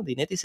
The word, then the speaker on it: Dynetics: Dynetics se dice así,